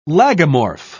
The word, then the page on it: lagomorph: British English